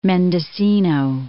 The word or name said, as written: Mendocino, Cape